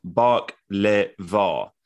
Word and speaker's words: baklava: Dino said baklava is pronounced bark-le-va, accents on first and third syllables. Think the Beatles, Drive My Car.